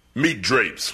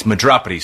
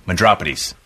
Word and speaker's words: Madropides: Who is Guy Meat Drapes? What kind of name is that? – It's Madropides, sir. It's Greek. – This says Meat Drapes. – It's actually pronounced Madropides.